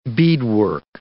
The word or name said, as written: beadwork